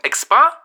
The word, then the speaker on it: экспа: Но Периодически в книге встречаются фразы типа «Разработческий жаргонизм экспа, ударение на А, происходит от experience point, очков опыта».